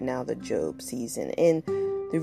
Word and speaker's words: Job: Now, for my listeners who are not familiar with the Bible, it's Job, J-O-B, it's spelled like job. But it's not Job. His name is Job, and there's a whole book titled after him.